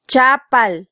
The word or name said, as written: chapal